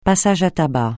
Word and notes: passage à tabac: francais

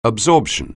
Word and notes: absorption: anglais

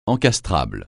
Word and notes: encastrable: français

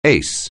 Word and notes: ace: anglais

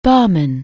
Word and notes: barman: anglais

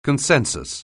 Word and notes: consensus: anglais

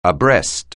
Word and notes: abreast: anglais

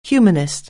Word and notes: humanist: anglais

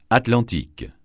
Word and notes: Atlantique: francais

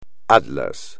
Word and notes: atlas: espagnol

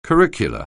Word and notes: curricular: anglais

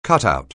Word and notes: cutout: anglais

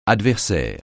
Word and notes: adversaire: French

